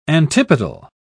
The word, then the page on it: antipodal: British English